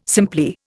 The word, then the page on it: simply: Indian